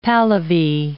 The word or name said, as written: Pahlavi